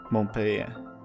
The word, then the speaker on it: Montpellier: Eh, ett spel utvecklat av Ubisoft Montpellier. Uttalar jag det rätt då tror ni? – Montpellier. Ja.